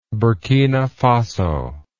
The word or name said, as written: burkina faso